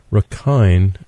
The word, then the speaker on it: Rakhine: It's pronounced Rakhine, as you told me before we got on the air.